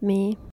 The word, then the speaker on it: My: And I'm Me, spelled M-Y, pronounced Me.